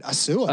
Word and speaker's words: Assoua: I don't know what that sound was, but that the Pittsburgh Maulers drafted Eric Assoua. Am I, am I butchering that name? Oh, you're good. Assoua. Uh, is that Assoua?